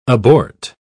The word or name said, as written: abort